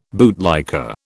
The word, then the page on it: bootlicker: British English